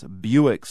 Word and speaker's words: Bewick: They're two swans, Buick swans to be precise. That's spelled B-E-W-I-C-K.